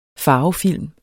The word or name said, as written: farvefilm